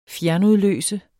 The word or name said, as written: fjernudløse